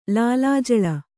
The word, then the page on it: ಲೀಲಾಜಾಲ: līlājāla